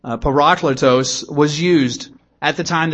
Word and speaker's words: parakletos: is the word parakletos. Parakletos. It's spelled P-A-R-A-K-L-E-T-O-S.